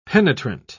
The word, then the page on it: penetrant: British English